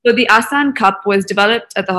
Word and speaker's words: Asan: So Asan, it's, you know, you might be familiar with the word.